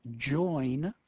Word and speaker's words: join: a word like join, J-O-I-N, will rhyme with the word line, L-I-N-E.